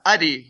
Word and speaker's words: Adi: Yeah, Adi. Yeah, Adi. I'd say emphasis on the A.